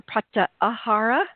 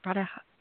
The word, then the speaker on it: pratyahara: So I want to get back to this pratahara because it or pratyahara. Am I saying that pratyahara? – Yeah, you're saying it right.